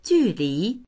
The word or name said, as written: ju4li2